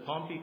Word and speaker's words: Pompey: After Pompey, I believe is the better pronunciation,